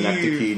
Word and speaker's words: Synecdoche: I love Synecdoche. I don't know if that's how you pronounce it. – I believe Synecdoche, New York.